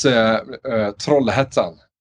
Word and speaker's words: Trollhättan: It's Trollhättan.